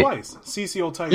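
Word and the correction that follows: Cicely: He said it wrong. It's Sicily, brother. Sicily.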